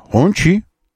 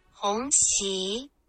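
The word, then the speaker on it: Hongqi: Вот, нам нужно было бы произносить это действительно аутентичненько, как это произносится на китайском, а именно, вот так вот. – Хонси. – Не хонцы, а вот так еще раз. – Хонси. – Хонси. Понятно вам?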